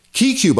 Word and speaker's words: KeyCue: KeyQ, by the way, KeyQ, that's spelled K-E-Y-C-U-E, and I've got a link in the show notes, is a Mac program that'll help you learn the shortcuts for any program.